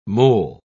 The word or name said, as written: mol